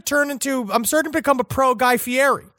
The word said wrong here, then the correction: Guy Fieri: Yeah, Fieri. Well, you're still not saying the name right, though, Guy Fieri. – I will not. – Okay. – I will not give him the Italian lilt, but I will give him the benefit of the doubt that he has not touched anyone against their consent.